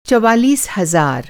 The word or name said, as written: चौवालीस